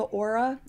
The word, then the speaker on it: Oura: So it's called the Aura. It's spelled O-U-R-A, and it's technology from Finland.